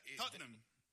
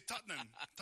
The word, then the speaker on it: Tottenham: Tottenham. Son dos sílabas. – Ajá. Es difícil, ¿no? – Nosotros los británicos, pero... – ¿Solo dos sílabas? – Sí, Tottenham.